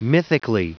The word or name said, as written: mythically